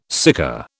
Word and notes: sika: British English

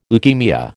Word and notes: leukaemia: American English

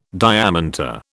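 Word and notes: diamanté: British English

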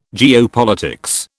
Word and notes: geopolitics: British English

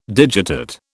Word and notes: digitate: British English

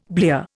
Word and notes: blear: Indian

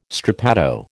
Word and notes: strappado: American English

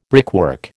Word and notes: brickwork: American English

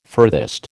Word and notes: furthest: American English

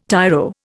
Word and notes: tiro: Indian

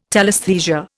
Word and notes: telesthesia: Indian

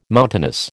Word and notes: mountainous: American English

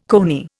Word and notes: coney: Indian

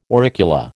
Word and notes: auricula: American English

